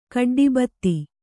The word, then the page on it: ಖಡ್ಡಿಬತ್ತಿ: Kannada